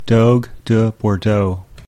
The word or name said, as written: Dogue de Bordeaux